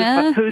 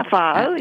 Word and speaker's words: pho: And I'm not sure if I'm saying it right. – I did? Pho? Okay. – Yeah, pho. You did.